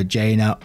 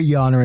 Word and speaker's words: Jana: And just before we get into it, this one does have a pronunciation of a name, J-A-N-A. And I know it can be pronounced a couple of ways, either Jaina or Yana. I'm going to go for Yana in this one.